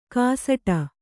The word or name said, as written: ಗಸಟು